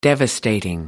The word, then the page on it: devastating: American English